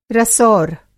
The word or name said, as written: रसौर